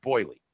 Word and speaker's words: Boille: Marcel Boilly, B-O-I-L-L-E. Pretty sure that's pronounced Boilly. – Okay. – Not Boyle, Boilly.